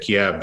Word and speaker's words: Kiev: So, I mean, we all say Kyiv instead of Kiev these days because Kiev's the Russian pronunciation and Kyiv's the Ukrainian pronunciation.